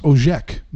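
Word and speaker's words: Ocak: January is pronounced Ojek.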